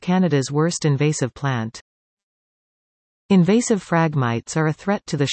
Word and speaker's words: phragmites: In 2005, invasive phragmites, pronounced phragmites, were recognized as this country's worst invasive plant by Agriculture and Agri-Food Canada.